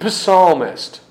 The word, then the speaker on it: psalmist: David is the sweet psalmist, though it's a silent p.